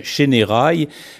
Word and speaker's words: Chénérailles: Oui, effectivement, on doit prononcer les « et », donc ce n'est pas « Chénérailles », mais c'est bien « Chénérailles ».